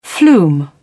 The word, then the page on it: flume: British English